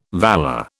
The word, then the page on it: valor: British English